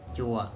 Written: coa

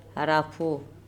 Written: arapho

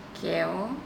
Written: keo

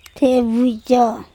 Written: THebucO

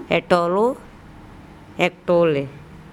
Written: eTolo ekTole

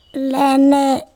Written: lEne

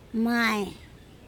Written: may

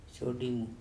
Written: soDimu

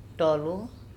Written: TOlo

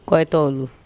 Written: koitOulu

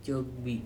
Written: cokbi

